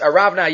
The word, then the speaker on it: Aravna: It's spelled Orna in Divrei Hayamim. It's Oren, which maybe is connected to the word Aron. The Aron was going to be there. But either way, in here, in Shmuel, it's pronounced Aravna Yavusi.